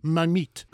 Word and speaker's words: Marmite: And also, she told me that Marmite is pronounced Mamite.